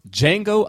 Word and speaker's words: Django: We are reviewing the film Django Unchained. The D is silent.